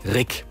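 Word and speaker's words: Ryck: Und hier in Greifswald haben wir einen Fluss, der wird auch gerne mal Rück oder Rieg ausgesprochen. Der heißt aber Rick.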